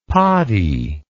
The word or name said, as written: poddy